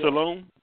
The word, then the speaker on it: Salone: S-A-L-O-N-E. – Did I pronounce that right? – Yes, you did. – Salone?